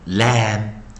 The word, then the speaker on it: lamb: 好，那我们来看看啊啊，在上一堂课我们教了 A M B，我们发音为 am am am，我们教的生词有 lamb lamb lamb。